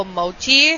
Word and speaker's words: mochi: A strawberry mango mochi.